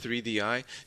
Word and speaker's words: 3DI: It's called 3DI, but is it Third Eye? Is that how you pronounce it? – No, it's pronounced 3DI.